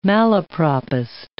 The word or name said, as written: malapropist